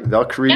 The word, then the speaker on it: Valkyrie: Valkyrie, yeah.